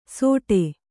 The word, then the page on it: ಸೋಟೆ: sōṭe